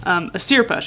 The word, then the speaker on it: Asirpa: So I might have been saying it wrong. Asirpa.